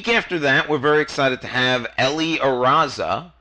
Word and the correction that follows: Areza: Areza. I even spelled it for you phonetically. – I screw up the guests' names all the time. Ellie Areza.